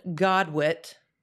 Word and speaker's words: godwit: This bird, a juvenile bar-tailed godwit.